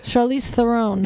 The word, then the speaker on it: Charlize Theron: Charlize Theron.